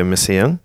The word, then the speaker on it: Messiaen: That, which is by Olivier Messiaen. – Yep. – Which I think I pronounced all that correctly.